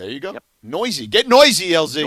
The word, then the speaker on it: Neuse: It's spelled Neuse, but he is Noisy.